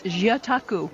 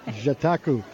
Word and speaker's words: Jataku: Now, before I murder the name of the activity you do, give it to me correctly pronounced. – It's Jataku. – Ah, see, I wouldn't have got the ja part. Jataku.